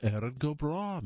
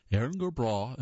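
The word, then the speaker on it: Erin go Bragh: Éireann go Breá, and by the way, that's spelled E-R-I-N space G-O space B-R-A-G-H, is a phrase you likely hear as St. Patrick's Day approaches, and other times of the year of your Irish descent.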